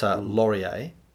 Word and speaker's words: Lorier: It's spelt L-O-R-I-E-R, and it's Laurier, I think, is because I saw it in brackets, you know, you pronounce it Laurier.